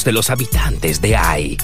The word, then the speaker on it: Hai: El nombre de la ciudad se pronuncia Ai y se escribe H-A-I. – Oh, claro, la ciudad se llama Ai.